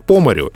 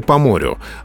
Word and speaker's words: по морю: Часто встречается фраза «по лесу» или «по лесу» по морю или по морю? Вот как правильно? Знаете, в эталонной речи очень часто ударение переходит на предлог.